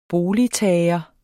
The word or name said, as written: boligtager